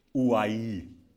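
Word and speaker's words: ouai (οὐαί): Now, woe, in the Greek, it's pronounced ouai. Ouai.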